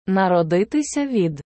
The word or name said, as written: народитися від